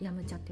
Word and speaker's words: 飲茶: 山茶って飲むお茶飲む茶って、やむ茶って読むんですけど、